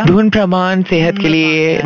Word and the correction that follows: धूम्रपान: Dhumrapan, say it again. – मुझे वो प्रनाउंस नहीं करना तो बहुत खतरनाक. धूम्रपान.